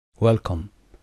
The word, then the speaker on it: welcome: دقت داشته باشید تلفظ welcome، صدای او اینجا شوا هست. در کتاب استارتر گفتیم صدای شوا هست، نه صدای او هست و نه صدای آ. ویلکم.